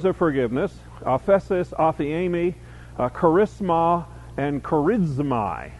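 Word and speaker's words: aphiemi (ἀφίημι): The words at the top, the one on the left, aphesis, is the word, is the noun, aphiemi is how you pronounce the other word, and that's the verb. The word charis, C-H-A-R-I-S, is the word for grace.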